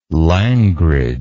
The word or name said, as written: langrage